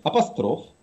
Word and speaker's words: апостроф: написано, что надо говорить апостроф, вероисповедание, жерло, зубчатый, инженерия, кровоточить, кухонный, ободрить, сливовый, ходатайство, исчерпать, исчевель.